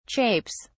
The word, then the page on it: chapes: British English